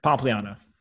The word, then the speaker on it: Pompliano: Pompliano, is that the right way to say it? – Close enough, yeah. – No. So say it how you would say it. – Pompliano. – Pompliano. Okay, great.